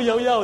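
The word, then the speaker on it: llaollao: Yeah, so you see, initially I also thought Lao Lao. You say, no, Yao Yao. It's like, well, okay. And you go to Causeway Point, the Lao Lao, Lao Lao. Yao Yao ice cream is like, wow, lots of long queue.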